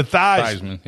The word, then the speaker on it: Theismann: And, you know, and of course, Joe Theismann changed the pronunciation of his name from Heisman to Theismann. – Yeah. – Well, so it would rhyme with Heisman.